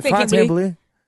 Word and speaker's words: Fontainebleau: Did I say that right? The Fontainebleau. – Yep. That was pretty good.